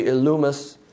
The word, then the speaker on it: Elymas: But Elymas the sorcerer, for so is his name by interpretation, Elymas, the Hebrew pronunciation is probably Elumus.